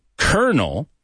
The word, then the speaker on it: colonel: Just think of that sentence as however ridiculous it may be, and you'll never pronounce the word colonel, colonel. Because if you pronounce it the way it's written, you're going to get confused. It's colonel, right? But no one says that. They say colonel, colonel.